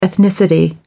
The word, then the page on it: ethnicity: British English